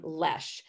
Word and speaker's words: Loesch: So you pronounce my last name Loesch. It is phonetically like nothing like you would teach children.